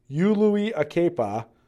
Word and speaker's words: Uliulakepa: You just say every vowel. U-Lui-Akepa. Okay, so there's your pronunciation guide. U-Lui-Akepa.